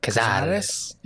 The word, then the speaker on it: Cazares: The head of the cartel, his name is Cazares, I believe that's how you pronounce it. – Cazares? – Cazares? – You gotta put some accent. – Cazares. So put a little accent on that. – Cazares? – Yeah.